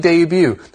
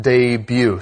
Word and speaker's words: debut: Of course, by now you know that this word is pronounced debut. So don't say that T at the end. D-E-B-U-T is debut.